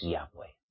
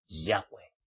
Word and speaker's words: Yahweh: Y fuera de ese nombre no hay salvación, y su nombre auténtico y verdadero es Yahweh. Yahweh. El que tiene el corazón verdaderamente redimido por la sangre del Cordero de nuestro Elohim viviente y creado es Yahweh, pronuncia bien el nombre de Yahweh.